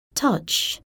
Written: touch

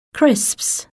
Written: crisps

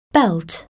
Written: belt

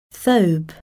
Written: thobe

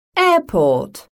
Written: airport

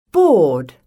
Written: board

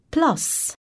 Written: plus

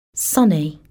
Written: sunny

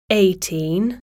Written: eighteen

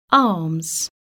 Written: arms

